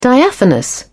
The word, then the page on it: diaphanous: British English